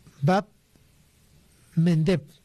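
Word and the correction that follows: Bab el-Mandeb: Bab el Mendeb. Es un poco difícil de pronunciar, ¿no?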